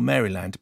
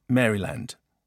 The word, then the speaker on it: Maryland: I am a senior at my school in Baltimore, Maryland. But then he adds, it's pronounced like Marilyn with a D on the end and not Maryland. Which makes it, according to Frankie, Maryland.